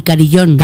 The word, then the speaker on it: carillón: Cari, carillón. – Carillón. – Carillón. – ¿Sabes qué digo carrillón? – Claro, yo he dicho carrión.